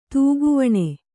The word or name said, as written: ತೂಗುವಣೆ